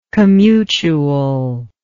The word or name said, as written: commutual